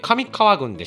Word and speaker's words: 上川郡: 上川郡でした、失礼しました。